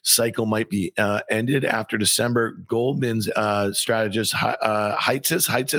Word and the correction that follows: Hatzius: is that how you pronounce it? – Jan Hatzius.